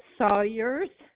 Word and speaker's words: Sawyers: Harold Sawyers. – You said Sarah? – Sawyers. S-A-W-Y-E-R-S. – All right. And your first name is spelled S-A-R-A-H, Sarah Sawyers.